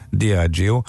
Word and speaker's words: Diageo: A helyes kiejtés Diageo. A név ugyanis a dia, mint nap, és a geo, geo, mint föld szavak összetétele, ezzel utalva a globális mi voltára. Köszi szépen. Szóval, Diageo.